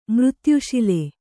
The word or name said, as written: ಮೃತ್ಯುಶಿಲೆ